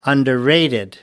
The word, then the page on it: underrated: British English